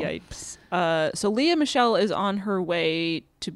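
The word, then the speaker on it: Lea Michele: Leah Michelle. Is that how you say her name? – Leah Michelle, yeah.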